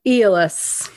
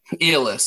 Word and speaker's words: Aeolus: How do you guys say it? Aeolus? Uh, Aeolus. Aeolus.